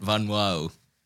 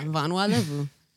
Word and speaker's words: Vanua Levu: part, you gotta well, roll like, your tongue kind of, real yeah, quick like, in a certain way to say it right. Vanua.